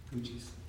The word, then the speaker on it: Gootjes: Kristen is G-O-O-T-J-E-S, very Dutch son. Gooches.